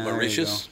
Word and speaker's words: Mauritius: I didn't know it was pronounced Mauritius. – Good for them. – And then what's Mauritius. – Mauritius. – That's how they say it, Mauritius.